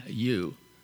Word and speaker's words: Tsidkenu: The Tsidkenu is T, capital T, Tsidkenu, and the T apparently is silent.